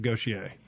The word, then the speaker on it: Gautier: My sons visited, and all right, so you can actually give a definitive pronunciation of Gautier.